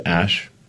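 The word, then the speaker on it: Æ: It's just X, the letter X, and then the AE is like pronounced Ash.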